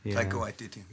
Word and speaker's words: Taika Waititi: Taiki Waititi, the director of that. – Taika Waititi.